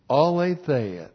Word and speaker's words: Aletheia: A-L, long E-T-H-E-I-A. Aletheia, that is the Greek word for absolute truth.